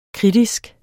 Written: kritisk

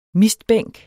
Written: mistbænk

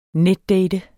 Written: netdate

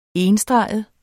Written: enstreget

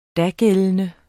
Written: dagældende

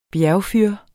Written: bjergfyr

